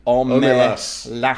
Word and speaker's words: Omelas: I'm looking up on Wikipedia, and it says. O-H-M-E-H-L-A-H-S-S. So Omelas. Omelas.